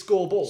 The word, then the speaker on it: Skurbull: His name is Scoreball? – Scoreball. – As in he scores? – Yeah, but it's pronounced that way, but it's spelled S-K-U-R... B-U-L-L.